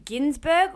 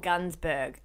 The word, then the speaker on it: Gunsberg: It is Ginsberg. It felt wrong falling out of my mouth. – Yes. I think a lot of people did call him that for a long time and he probably just started correcting them. – Because it's spelt with a U, but it is not sounded out.